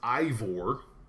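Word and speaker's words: Eivor: Number one, the character's name is pronounced Ivor.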